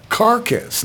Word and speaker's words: Carcass: É, Carcass, né? Eu falei Carcass. É isso aí, Carcass.